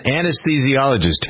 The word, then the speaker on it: anesthesiologist: Anesthetologist, that's how you pronounce it, anesthesiologist, him too.